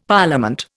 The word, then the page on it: Parliament: Indian